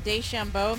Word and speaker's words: DeChambeau: Well, while we're on the topic of geopolitical conflicts, Phil Mickelson and Bryson DeChambeau, am I saying that right? – Yeah.